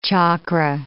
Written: chakra

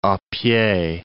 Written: a pied

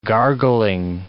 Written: gargling

